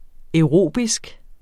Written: aerobisk

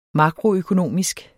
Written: makroøkonomisk

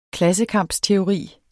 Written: klassekampsteori

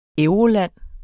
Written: euroland

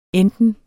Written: enten

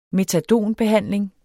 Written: metadonbehandling

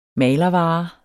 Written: malervare

